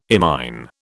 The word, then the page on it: imine: British English